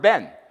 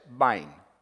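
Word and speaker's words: Bain (בן): It's the word ben that we would recognize. It's not pronounced that in the Hebrew. It's pronounced like this. Bain is the way it's pronounced in the Hebrew.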